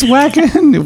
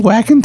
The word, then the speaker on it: Wacken: Is this Wacken? What? Like, this is pronounced Wacken, but. – Yes, in Germany, their W's are pronounced as V's. – Yeah. He's like, oh, are you whacking today?